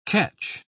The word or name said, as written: ketch